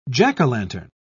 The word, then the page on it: jack-o'-lantern: British English